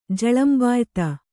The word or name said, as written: ಝಳಂಬಾಯ್ತು